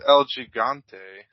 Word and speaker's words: El Gigante: He tells me who is Ali Gante. – Because it's El Gigante.